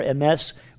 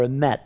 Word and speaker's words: Emet: They're called Hesed Shel Emat, or Emes, or Emet, depending on how they pronounce it.